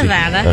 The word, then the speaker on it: Nevada: It's Nevada. – Okay. – If you're in Missouri, it's Nevada.